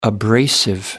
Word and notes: abrasive: British English

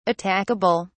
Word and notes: attackable: British English

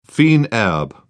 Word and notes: fines herbes: British English

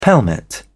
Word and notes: pelmet: British English

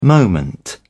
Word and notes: moment: British English

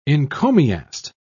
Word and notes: encomiast: British English

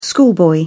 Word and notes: schoolboy: British English

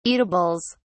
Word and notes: eatables: British English

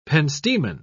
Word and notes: pentstemon: British English

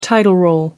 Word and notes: title role: American English

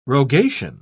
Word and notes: rogation: British English

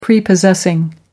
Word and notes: prepossessing: American English

